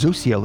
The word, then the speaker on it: Zosiel: Now, this name phonetically is Zosiel. That's what it says, Zosiel.